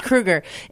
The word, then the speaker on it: Kroeger: We just had a conversation about the pronunciation of Chad's last name. It's Kroeger.